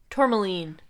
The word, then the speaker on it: Tourmaline: Tourmaline. – Tourmaline. – Look at Excuse this me. Moonstone Ooh. – Tower. Isn't that pretty? – Yeah. – It's a rainbow moonstone. – Tourmaline.